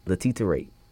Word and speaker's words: Letitia Wright: And there was, Letitia Wright. I think that's how you say her name. – Right? Something like that. – Yeah, Letitia Wright.